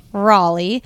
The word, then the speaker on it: Raleigh: Raleigh.